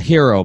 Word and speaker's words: Herro: A hero, by the way. He told me that's how he wants to pronounce.